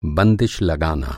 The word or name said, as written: बंदिश